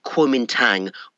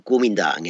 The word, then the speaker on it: Guomindang: And also, crucially, they ally with the biggest non-communist party in China, the Chinese Nationalist Party, sometimes known as the Kuomintang or Guomindang in the Chinese pronunciation, under its leader Sun Yat-sen.